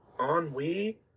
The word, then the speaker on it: ennui: There was only just because I saw Ennui. Am I pronouncing it right? E-N-N-U-I.